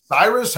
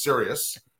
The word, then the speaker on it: Sirius: And, you know, some people are like, oh, you're leaving FAN for Cyrus. How do you pronounce it? I'm like, no, it's Sirius.